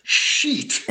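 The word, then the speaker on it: Scheat: So we came up with Shiat. – I like it. – Yeah. – I like it. – Is that close? – It's much better than the correct pronunciation that's sheet.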